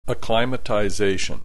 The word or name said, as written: acclimatization